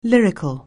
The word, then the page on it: lyrical: anglais